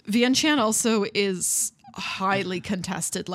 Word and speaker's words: Vientiane: which is now called Vientiane. That I looked up, so I know I'm pronouncing it right.